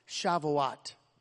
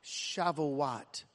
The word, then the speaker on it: Shavuot: let's say the word so we can pronounce it. Shavuot. Say it with me. Shavuot. I'll do it again. Shavuot.